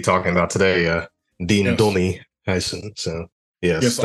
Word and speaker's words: Huijsen: But then Huijsen. I mean, and I would say Huijsen. Okay. It's just I guess the way it's spelled. It's U-I-J instead of U-Y. I guess anyway. Huijsen is how I would say it.